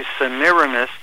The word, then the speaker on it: Semiramis: Now, we sometimes call her Semiramis, but I think the better pronunciation would be Semiramis, and there are all kinds of, ugly stories associated with this lady.